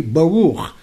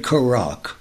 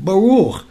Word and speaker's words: Baruch: I told him to say Baruch. He said Karak. No, I said, Baruch. He repeated Tokak.